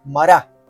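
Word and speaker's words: Mará (מרה): Por eso le pusieron el nombre de Mará, es la pronunciación en hebreo.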